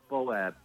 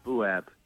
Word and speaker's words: Bouab: Uh, Bouab, Bouab.